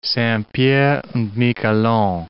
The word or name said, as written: Saint-Pierre and Miquelon